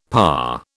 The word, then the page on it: parr: British English